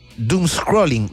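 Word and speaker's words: doomscrolling: Hoy con nuestro columnista Francisco Parada, ahí en torno a las 8:30 de la mañana, vamos a estar conversando del doom scrolling. ¿Lo dije bien? Doom scrolling. Bueno, es doble D-O-O-M-S crawling. Bueno, sí, está bien dicho.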